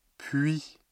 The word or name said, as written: puis